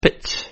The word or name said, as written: pitch